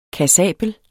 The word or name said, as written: KASSABELT